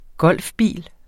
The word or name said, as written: golfbil